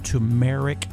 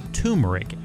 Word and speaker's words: turmeric: But, you know, probably the most well-researched herb on earth for health benefits is curcumin or turmeric. Some people pronounce it turmeric. Either one is correct.